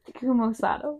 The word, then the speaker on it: Takuma Sato: Takuma Sato.